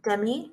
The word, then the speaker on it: Demi: Demi Moore? Is that – did I say that right? – It's Demi. – Why did that just sound wrong to me? – Demi? – No, that was right.